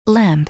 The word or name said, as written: lamp